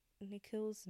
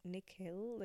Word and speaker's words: Nikhil: Another thing I did want to talk about on the topic of names is that Nikhil's name is still mispronounced even though it's 2 syllables, like Nikhil, like, people pronounce it like Nikhil and stuff in the book, or, like, they just shorten it to Nick, which, like, guys, it's 2 syllables, like, it's not that difficult, I think, but whatever.